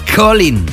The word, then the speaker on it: Colyn: Se llama Colin. Ha estado sonando muchísimo últimamente, se escribe C-O-L-Y-N.